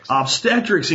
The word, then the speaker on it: obstetrics: Obstetrics and gynecology, as well as a fellow of the American College of Surgeons for many years.